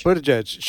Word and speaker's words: Buttigieg: How do you pronounce Buttigieg. – It? – Buttigieg.